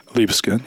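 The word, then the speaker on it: Libeskind: It's by Daniel, if I'm saying his name incorrectly, correct me, it's Liebeskind? – Liebeskind, yeah.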